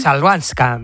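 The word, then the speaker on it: Chwalińska: Joder, ¿cómo se dice? Es complicada, ¿eh? Chabalinska. – ¿Eh? Chalvanska. – Chalvanska.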